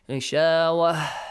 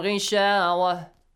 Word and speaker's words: غشاوة: Make sure you do not pronounce that has so kind of clearly. غشاوة. No, don't need to release breath, okay? For example, this way. وعلى أبصارهم غشاوة. Simple, not long has sound, okay?